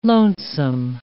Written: lonesome

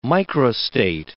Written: microstate

